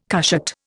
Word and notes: cushat: Indian

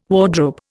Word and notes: wardrobe: Indian

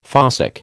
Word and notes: fossick: American English

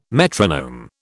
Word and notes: metronome: British English

